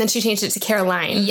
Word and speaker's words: Caroline: And in the South, that people pronounce it Carolyn. So when we moved down to the South, everyone pronounces Caroline, even though you can see the word line is Carolyn.